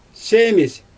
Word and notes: seemIsJ: Xyzyl

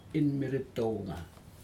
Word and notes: inmeritoga: Aneityum

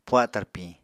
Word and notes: puha tarpiñ: apatani

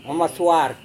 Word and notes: maswar: Serewen

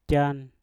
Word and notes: jan: Mpur

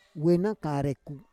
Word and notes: we na kareku: Roon

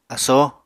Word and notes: asoo: Apatani

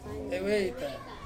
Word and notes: veita: Ansus

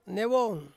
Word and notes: newong: Ansus

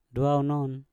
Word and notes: dwau non: Mpur

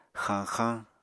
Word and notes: xañxañ: Apatani

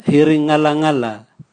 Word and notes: hiringalangala: Raga

